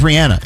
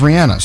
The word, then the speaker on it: Rihanna: It is Rihanna. – It's Rihanna. – People say, no, it's Rihanna. You know, but if you didn't know better, Rihanna seems like the natural way to say it, but she says Rihanna. So let's go with the way she says it, right? – Yes.